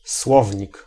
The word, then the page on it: słownik: /ˈswɔvɲik/